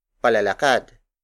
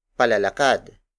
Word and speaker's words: palalakad: For example, palalakad, palalakad, palalakad. Palalakad is spelled as P A L A L A K A D.